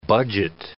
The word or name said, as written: budget